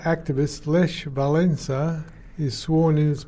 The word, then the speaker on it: Lech: Lech. L-E-C-H. – Lech. – You got to pronounce Lech.